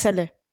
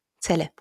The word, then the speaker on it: Celle: Februar 1958 in Celle. Spricht man das so aus? Ich weiß es. Celle. Celle, genau.